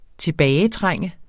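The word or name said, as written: TILBAGETRÆNGT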